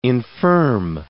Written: infirm

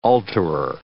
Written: alterer

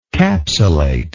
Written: capsulate

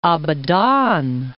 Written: Abadan

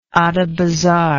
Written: Adabazar

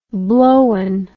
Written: blowen

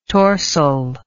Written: torsel

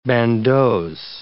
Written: bandeaux

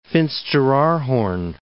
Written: Finsteraarhorn

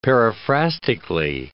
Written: periphrastically

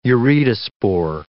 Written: uredospore

